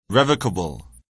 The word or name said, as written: revocable